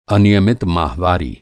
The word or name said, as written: अनियमित